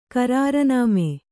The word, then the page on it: ಕರಾರುನಾಮೆ: karārunāme